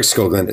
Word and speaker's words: Skoglund: Is it Eric Skoglund? Is that how you pronounce it? – Yep. – All right. Eric Skoglund.